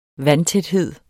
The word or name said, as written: vandtæthed